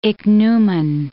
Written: ichneumon

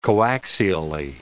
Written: coaxially

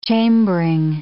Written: chambering

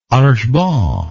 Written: archebanc

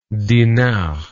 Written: dinard

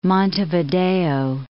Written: Montevideo

